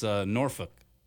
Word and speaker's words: Norfolk: This texter, have you ever heard Virginia people pronounce Norfolk? It sounds vulgar, but perfectly correct. It's Norfolk.